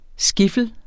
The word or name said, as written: skiffle